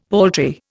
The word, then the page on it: paltry: Indian